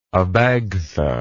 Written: Abagtha

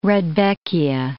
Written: rudbeckia

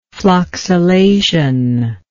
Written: floccillation

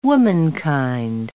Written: womankind

